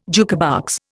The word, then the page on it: jukebox: Indian